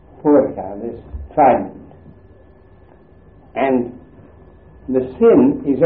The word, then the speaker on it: sin: And if you put it on the dexter side, it is pronounced sin.